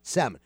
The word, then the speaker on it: salmon: Salmon. What is this guy with... Both of you, with the L. Forget the L. It's salmon. The L is silent.